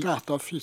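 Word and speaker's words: Cattafi: Cataphi, c'est sur la première syllabe, non ? Accentué. – Ouais, moi je l'accentue sur la première parce qu'il y a deux T.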